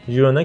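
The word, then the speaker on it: Girona: حالا چرا ژیرونا خیرونا اینا کلا تو منطقه کاتالونیا زبان کاتالان اون جی اول و ژ میخونن اگه همین بره تو زبان کاستیان یا زبان مادریدی خ تلفظ میشه یعنی اگر ژیرونا باشگاه مادریدی بود میگفتیم خیرونا مثل خطافه که اولش جیه ولی چون مال تیم شهر مادریده میگیم خطافه نمیگیم ژتافه ولی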